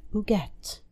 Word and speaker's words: Huguette: The pronunciation took some getting used to, and my southern accent still has trouble with it. I'm told that the French U doesn't exist in English. It's not Huguette with an initial H sound, nor Huguette with a Y, but somewhere close to Huguette.